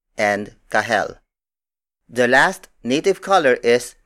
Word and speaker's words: kahel: Another Tagalog word for orange is kahel.